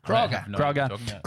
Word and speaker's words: Crogga: Kroger, C-R-O-G-G-A. How would you say that? Kroger? – Kroger.